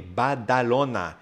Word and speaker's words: Badalona: No, it's actually B-A-D-A-L-O-N-A, Badalona, another place.